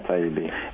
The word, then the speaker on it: Taibbi: This is an article by, I hope I pronounced his name right, Matt Taibbi. – Yeah, Taibbi.